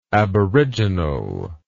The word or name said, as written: aboriginally